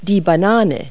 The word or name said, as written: banana